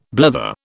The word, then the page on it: blether: British English